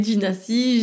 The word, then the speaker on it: Ginacie: mais qui s'écrit Dina euh, Gina Si, puisqu'on peut pas mettre d'esperluette sur euh, Instagram, donc c'est Gina Si, G-I-N-A-C-I-E.